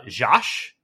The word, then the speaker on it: Georges: Uh, it's an adaptation of, whew, okay, uh, Josh, is how you pronounce that. Uh, Georges, but French is Josh.